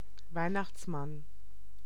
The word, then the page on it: Weihnachtsmann: [ˈvaɪ̯.naxts.man]; /ˈvaɪ̯.naxt͡s.man/